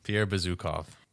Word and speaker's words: Bezukhov: Pierre, how do you say the last name? – Pierre Bezukhov. – Bazooka? – Yeah.